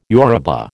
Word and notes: Yoruba: American English

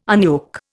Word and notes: unyoke: Indian

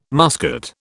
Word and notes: muscat: British English

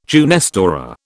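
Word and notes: jeunesse dorée: British English

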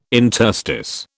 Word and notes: interstice: British English

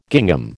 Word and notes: gingham: American English

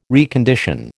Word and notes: recondition: American English

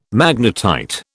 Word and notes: magnetite: British English

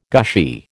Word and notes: gushy: American English

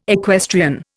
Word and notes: equestrian: Indian

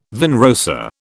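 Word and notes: vin rosé: British English

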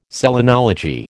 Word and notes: selenology: American English

